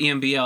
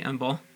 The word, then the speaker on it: EMBL: One more thing about I always call it EMBL. I had no idea that you pronounced it, actually, EMBL.